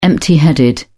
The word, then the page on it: empty-headed: British English